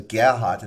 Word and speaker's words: Gerhard: And so, like, when you have Afrikaners who have names like Gerhard, which is a German name. So Gerhard, and that's how you pronounce it in German.